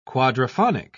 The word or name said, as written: quadraphonic